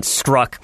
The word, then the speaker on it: Strzok: Most people are pronouncing it Strzok, but it's spelled like Sturzok or something like that.